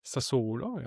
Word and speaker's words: Sassuolo: Ja, aber Antist hast du ja vorher angesprochen, Rückkehr nach Sassuolo, habe ich sie richtig ausgesprochen? Sassuolo, ja.